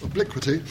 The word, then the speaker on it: Obliquity: And his latest book, which I'm told should be pronounced Obliquity, I was toying with Obliquity, but it's Obliquity, was published in 2010.